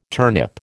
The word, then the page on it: turnip: American English